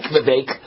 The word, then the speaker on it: Vivek: I like Vivek. Vivek, I like him. It rhymes with cake. Vivek, that's how I remember it.